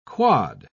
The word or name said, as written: quod